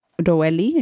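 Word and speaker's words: Roheli: Okay, Benjamin's son, Roeli, I think is how you pronounce it. It's R-O-H-E-L-I.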